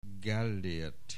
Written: Gellért

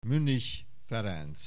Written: Münnich, Ferenc

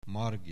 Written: Margit

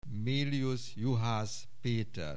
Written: Méliusz Juhász Péter

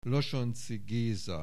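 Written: Losonczy Géza

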